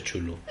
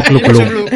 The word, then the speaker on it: Cthulhu: es el nombre impronunciable. Nosotros siempre le hemos dicho chulu. Eh, este, en esta casa somos muy... – El chulu. – Yo digo clu clu.